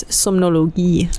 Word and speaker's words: Somnologie: By the way, in German, the G is a hard G, so it's Somnologie.